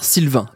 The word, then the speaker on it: Sylvqin: Ce confrère donc, c'est le youtubeur Sylvain. Attention, ça s'écrit S-Y-L-V-Q-I-N.